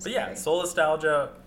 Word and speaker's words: solastalgia: I think it's pronounced solastalgia. – All right. – So like nostalgia, but...